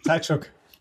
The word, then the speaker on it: Tkachuk: Tkachuk.